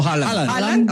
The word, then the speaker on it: Haaland: ¿O Haaland? – No, Haaland.